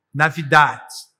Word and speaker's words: Navidad: É, como é que é? Navit. Fala aí. Navidad. Navidad.